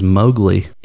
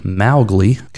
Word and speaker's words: Mowgli: Yeah, so Mowgli is actually Mowgli, kind of like, you know, the first part of his name, M-O-W, rhymes with cow, Mow. So Mowgli and not Mowgli.